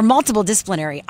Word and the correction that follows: multidisciplinary: I always say that, but now I guess I've been saying it wrong the whole time. It is multidisciplinary.